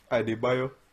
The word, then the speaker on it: Adebayo: I think I'm saying that right. – Adebayo.